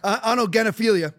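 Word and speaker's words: autogynephilia: So what you just heard, what was it pronounced again? Auto what? Gynophobia? – Genophilia. – Autogenophilia. – Yeah.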